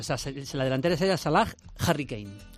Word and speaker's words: Salah: Oye, lo correcto es Salah.